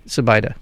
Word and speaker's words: Zubaida: I've been saying his name wrong, but you're right, Zubaida.